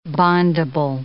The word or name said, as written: bondable